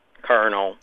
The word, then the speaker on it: colonel: The L is pronounced like an R. – It has to do with both the military and chicken. – Colonel.